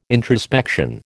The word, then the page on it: introspection: American English